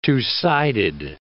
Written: two-sided

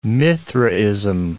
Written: Mithraism

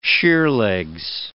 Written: sheerlegs

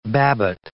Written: Babbitt

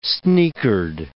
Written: sneakered

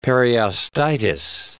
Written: periostitis

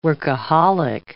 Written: workaholic

